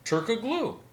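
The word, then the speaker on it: Türkoğlu: The G is silent, you uncultured swine. – Dude, I thought you were a Turkoglu fan. – I am. Turkoglu. – Turkoglu. – Turkoglu. Turkoglu. It's not a glue. It's not Gorilla Glue.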